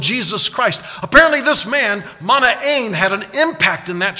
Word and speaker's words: Manaen: I didn't know, so I went to my Greek New Testament, and I'm pronouncing it the way it is written in Greek. Manaen. Say that with me, please. Manaen. Emphasis on the last syllable. Manaen.